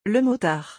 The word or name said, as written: le motard